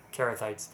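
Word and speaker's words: Cherethites: I don't think I've ever known how to say that one. Sherethites? – Hard C. – Huh? – Hard C. – Hard C. – It would be a little guttural. It would be Carathites. Uh-huh. It's Crete, the Isle of Crete.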